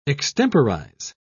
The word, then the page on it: extemporise: British English